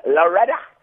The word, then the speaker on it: Loretta: You must pronounce it properly. It's Loretta. Loretta. – Oh, I shouldn't say like a South African there, hey?